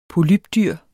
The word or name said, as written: polypdyr